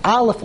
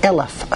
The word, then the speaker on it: Aleph: Aleph means one, but Aleph also can be pronounced Eleph, a thousand.